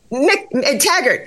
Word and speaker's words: McTaggart: McTaggart. Yes, McTaggart. Yeah. It's still early here in San Diego. Target, nope. McTaggart.